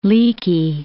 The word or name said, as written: Leakey